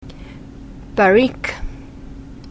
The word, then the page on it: Barrique: French